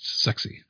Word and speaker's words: SCSI: Matt, also in our chat, has confirmed that Shugart were pushing for this SCSI to be pronounced sexy, to follow on from sassy, S-A-S-I.